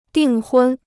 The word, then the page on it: 订婚: dìng hūn